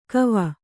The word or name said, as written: ಕವ